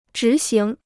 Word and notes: 执行: zhí xíng

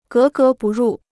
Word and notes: 格格不入: gé gé bù rù

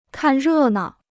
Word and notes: 看热闹: kàn rè nao

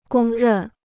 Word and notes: 公认: gōng rèn

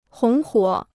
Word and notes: 红火: hóng huǒ

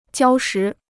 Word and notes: 礁石: jiāo shí

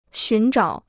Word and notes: 寻找: xún zhǎo

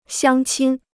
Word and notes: 乡亲: xiāng qīn

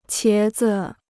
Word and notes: 茄子: qié zi

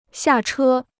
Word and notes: 下车: xià chē

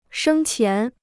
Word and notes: 生前: shēng qián

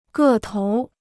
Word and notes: 个头儿: gè tóur

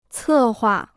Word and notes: 策划: cè huà

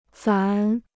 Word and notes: 咱: zá

